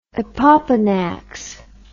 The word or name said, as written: opopanax